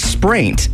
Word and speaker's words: spraint: What is spraint? S-P-R-A-I-N-T.